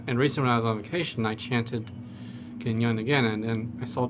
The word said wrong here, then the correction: Kuan Yin: And just to step in, that's Kuan Yin, right? – Kuan Yin, yes. I couldn't pronounce it.